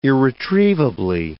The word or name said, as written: irretrievably